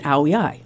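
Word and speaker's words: áo dài: And she said, "It's an ao yai."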